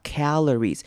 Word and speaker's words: calorie: calorie 这个字要注意的是开头的 A 短母音 A 的声音 calories calories，那个 A 记得把嘴巴稍微张大 calories。接下来呢是 OR，这边 OR 我们要念 ERR 的声音，不是 OR，所以是念 calorie calorie，不要念成 ORE，所以是 calorie。 A calorie is a unit of energy.